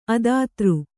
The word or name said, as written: ಅದಾತೃ